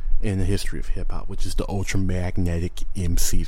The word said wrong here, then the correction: Ultramagnetic MCs: Why did I say it like that? Ultramagnetic MCs.